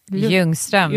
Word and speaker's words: Ljungström: Caroline Ljungström. Am I saying that correctly? – Ljungström, yes, the Swedish name.